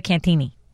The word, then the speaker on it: Cantigny: So the Cantigny golf club pronounces it Cantigny.